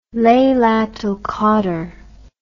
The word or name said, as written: Lailat ul-Qadr